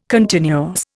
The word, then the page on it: continuous: Indian